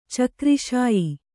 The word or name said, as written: ಚಕ್ರಿಶಾಯಿ